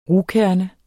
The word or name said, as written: rugkerne